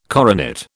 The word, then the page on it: coronet: British English